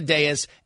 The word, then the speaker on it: dais: The reality is you're getting up on a dais is the pronunciation. You're getting up on a dais, and it's an introductory press conference.